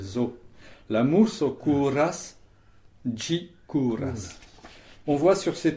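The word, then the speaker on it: ĝi: Et pour le neutre, on dit ji, ça s'écrit G chapeau I.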